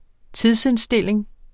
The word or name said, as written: tidsindstilling